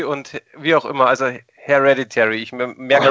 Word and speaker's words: Hereditary: Das heißt, du bleibst bei, ich muss jetzt, wie, sag nochmal bitte, wie sprichst du diesen Film aus, wo du gesagt hast, den findest du super, weil ich habe das noch nie aussprechen können. Hereditary. Hereditary, okay, aber ja.